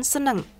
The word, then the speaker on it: senang: In a daily conversation, Indonesian people usually say the word senang with pronunciation seneng. An expression aku seneng is the same with aku senang or saya senang.